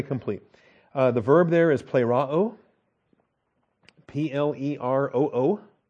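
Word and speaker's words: plēroō: It comes later just because the paradigms are tougher, but plero is the verb. It's a long E, the eta, P-L-E-R-O-O, plero, with 87 New Testament uses.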